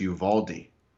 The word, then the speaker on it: Uvalde: Jason, within 3 seconds, he knows all this stuff about government conspiracy, yet Bryce Mitchell doesn't even know that the town is pronounced Uvalde.